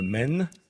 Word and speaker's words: Maine: Cela s'écrit de la même façon et cela se prononce de la même façon. – Le Maine ? – Mais oui, c'est l'État du Maine.